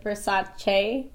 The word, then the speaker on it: Versace: I have to say it right. How do you say it? Versace. – There you go.